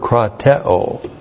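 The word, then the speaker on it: krateō: This is a very special verb, krateo. K-R-A-T-E long O. Krateo.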